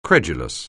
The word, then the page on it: credulous: anglais